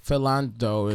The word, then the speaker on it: Philando: How do you pronounce his name? Is Philando? – Philando is Castile.